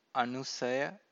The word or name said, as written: anusaya